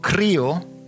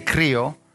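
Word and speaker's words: krio (χρίω): It's from a word called krio. Everybody say krio. I want to hear the R's. Krio. Yeah, come on. Roll your R.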